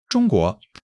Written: 中国

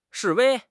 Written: 示威

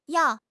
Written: yao4

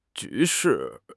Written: 局势

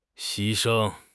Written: 牺牲

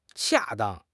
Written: 恰当

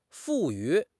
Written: 赋予

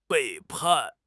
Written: 背叛

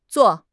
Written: Zuo4

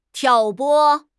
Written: 挑拨

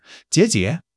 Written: jie3 jie5

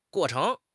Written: guo4 cheng2

